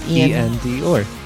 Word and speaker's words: Iann Dior: Ian Dior. I'm saying R with an accent. – Well, it's spelled I-A-N-N-D-I-O-R.